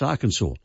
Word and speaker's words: Arkansas: So Kansas is pronounced Kansas, but the one next door, Arkansas, is pronounced Arkansas.